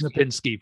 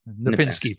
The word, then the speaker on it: Knapinski: It's Knapinski. Knapinski. First K is silent.